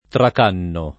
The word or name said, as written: tracannare